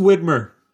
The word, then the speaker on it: Widmer: Shout out to the Widmer Brothers. – Yeah, you know, shout out, man. It'll be is it Widmer? Widmer? Widmer? – It's just Widmer.